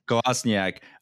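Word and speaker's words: Kolašinac: Kostyanek. I can't still say his name. Yeah, Kolasinac.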